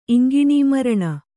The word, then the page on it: ಇಂಗಿಣೀಮರಣ: iŋgiṇīmaraṇa